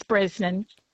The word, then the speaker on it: Brosnahan: Spelt Brosnahan, but the Irish, Dad always said the Irish pronunciation is Brosnan.